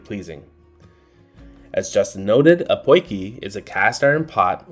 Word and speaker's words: potjie: Poiki is spelled P-O-T-J-I-E, and is pronounced poiki.